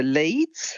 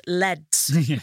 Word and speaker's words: Leeds: I'm going to be going to Leeds. – We've heard of that. It's actually pronounced Leds.